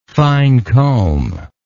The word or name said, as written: fine comb